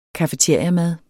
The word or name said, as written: cafeteriamad